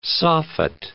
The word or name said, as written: soffit